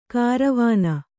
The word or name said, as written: ಕಾರವಾನ